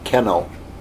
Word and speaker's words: keno: And what it says is, keno, point B, that bold print, the Greek word, here's how you say it, keno, keno. Sounds like where you put your dog, keno, keno, okay?